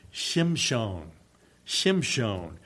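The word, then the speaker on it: Shimshon, Shimshon (שמשון): The Hebrew pronunciation would be Shimshon, Shimshon.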